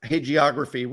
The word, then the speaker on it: hagiography: Is that a soft G in that word? I have to admit that. – I thought it was. I don't know. Hagiography.